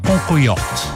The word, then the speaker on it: Cancoillotte: Parliamo di un formaggio locale francese che si chiama Concoyot. Si scrive Cancoillotte.